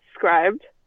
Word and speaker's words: Scribd: So they, in the survey, they said, oh, how would you feel about a bundle with Hulu and with Scribd? I think that's how you pronounce it, S-C-R-I-B-B. – Yeah. – Yes. – Scribd or Scribd or something like that.